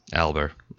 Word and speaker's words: Alber: Alber. A-L-B-E-R. – I always want to put a T on there. No T. It's not Fat Albert. It's Matt Alber. – No T, no Albert.